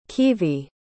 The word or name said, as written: Keavy